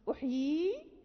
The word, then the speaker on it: أحيي: أحيي هذه يعني مرسومة بياء واحدة فقط، ولكن نحن ننطق ب يا أين، يعني ف وصلا ووقفا.